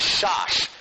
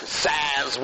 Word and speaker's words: Szasz: Um, and arguments have been made, notably by a, a, a, a psychiatrist in the 60s um, whose name is probably pronounced something like Shosh um, a Hungarian name. Anybody happen to know the, the right answer? Anyway, Thomas Szasz will do, um, or Shosh, or something.